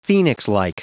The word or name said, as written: phoenixlike